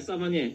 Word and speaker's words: sommelier: Yeah, sommelier. Did I pronounce that right? – Yeah, you said it right, but you got to break it down for people that, like me.